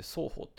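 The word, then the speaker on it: 双 方 (ソーホー): で、これについては、クラブディストリクト、まあ、ロンドン中心街、中心地区、双方というところ、 SOHO で双方って読むんですけど、双方っていうところがまあ有名なんですよ。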